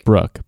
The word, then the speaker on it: Bailey Brooke: Now, today we have, correct me if I'm saying this wrong, Bailey Brooks. – No S, just Brooke. – Brooke. Bailey Brooke in the podcast studio today.